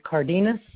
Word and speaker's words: Cardenas: Daniel, D-A-N-I-E-L, and last name is Cardenas, C-A-R-D-E-N-A-S.